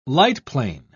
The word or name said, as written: lightplane